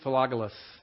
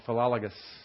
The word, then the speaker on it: Philologus: Greet Philologus. I said that wrong. Philologus.